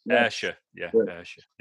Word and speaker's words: Ayrshire: Ayrshire.